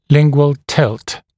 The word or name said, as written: lingual tilt